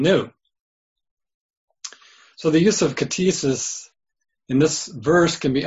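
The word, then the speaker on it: ktisis: I'll spell it K-T-I-S-I-S. K-T-I-S-I-S. Katesis is pronounced.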